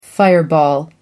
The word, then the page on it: fireball: American English